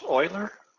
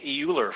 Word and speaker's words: Euler: Is that how that's pronounced, Euler? Okay. I've been calling it Euler for a while, but it's Euler.